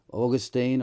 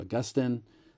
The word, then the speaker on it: Augustine: It's a great debate that happened in church history between Augustine or Augustine, as depending on your pronunciation, and a man called Pelagius.